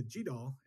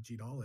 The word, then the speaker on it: GDAL: And so he changed kind of his standing pronunciation to GDAL. And I think the sort of original take of how you might read it would certainly be to say it like GDAL. And you'll of course hear it all pronunciations.